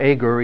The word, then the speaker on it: Agur: You can pronounce his name either Agur or Agur. Either one is acceptable.